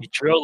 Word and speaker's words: Ichiro: His name was Ichiro. I think is that a first name, or is that a proper first name, Ichiro? – Ichiro, like the baseball player?